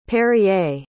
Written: perrier

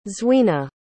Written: zweena

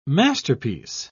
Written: masterpiece

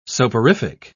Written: soporific

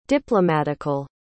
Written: diplomatical

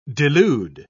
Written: delude

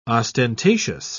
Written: ostentatious

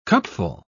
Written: cupful

